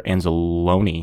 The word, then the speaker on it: Anzalone: Because as much as we may not want to hear it, I'm pretty sure Anzalone, did I say that right? – Yeah.